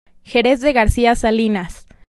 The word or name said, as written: Jerez de García Salinas